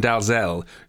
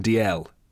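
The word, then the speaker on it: Dalziel: There you I got hauled over the coals this week for pronouncing Gordon Dalzell. It's the correct pronunciation is Gordon DL.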